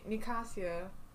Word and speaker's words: Nicasia: Nicasia...